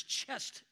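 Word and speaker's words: chesed: And that word loving kindness is chest in the Hebrew, which rhymes with blessed.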